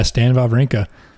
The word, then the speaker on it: Wawrinka: It was your boy Wawrinka. Is that how you say it? – Yeah, Stan Wawrinka.